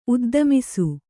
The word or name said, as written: ಉದ್ದಮಿಸು